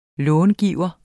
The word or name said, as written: långiver